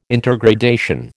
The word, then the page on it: intergradation: American English